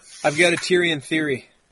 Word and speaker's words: Tyrion: But Tyrion, how do you say his name? – Yeah, that's right.